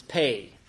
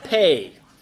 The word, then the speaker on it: Pe: I mean, the pronunciation is pay. First thing I say, man, I know it's not P, and thankfully we don't have young children here because they would have a hard time saying that without grinning, but it's pronounced pay.